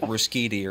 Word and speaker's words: Ruscitti: Do you say your last name Ruscitti? – Yeah, very good. It's Ruscitti. – Perfect. Ruscitti, yeah. Pronounce it right. – Well, yeah, but I grew up with a lot of people. A lot of people would have Americanized it to like Ruscitti or something.